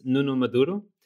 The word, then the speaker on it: Nuno Maduro: So my name is Nuno Maduro.